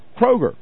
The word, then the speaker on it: Kroger: Actually, Kroger, with an R. We don't have Kroger here in Tallahassee, so I don't pronounce it correctly, but it's Kroger.